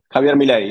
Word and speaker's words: Javier Milei: Javier Milei, yeah.